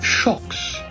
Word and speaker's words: Xocs: Shocks is spelled X-O-C-S in the English, but it is pronounced Shocks, and they were from Spain.